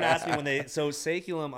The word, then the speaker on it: Saeculum: called uh, Saeculum. Correct. Is that how you is that how that's you pronounce yeah, it? Yeah, actually, you're okay. probably the first one to actually say it right the first time.